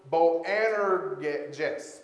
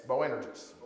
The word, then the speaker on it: Boanerges: Okay, well, Jesus called James and John Boanerges. Boanerges. – Boanerges? – Thank you.